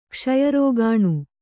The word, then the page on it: ಕ್ಷಯರೋಗಾಣು: kṣaya rōgāṇu